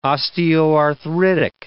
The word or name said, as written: osteoarthritic